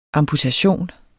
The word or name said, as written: amputation